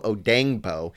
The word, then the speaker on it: Odeyingbo: is Vanderbilt's Dayo Odeyingbo. Uh, I had to kind of include the pronunciation there. But Dayo Odeyingbo,